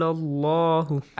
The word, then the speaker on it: الله: إِذْ قَالَ اللَّهُ. – أحسن. The letter لام in the word Allah is pronounced heavily.